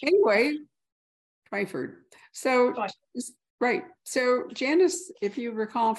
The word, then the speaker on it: Twyford: Is it Twyford or Twyford? I never even thought to ask you. – It is Twyford.